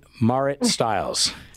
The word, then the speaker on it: Marit Stiles: Marit Stiles? – Yeah, you are.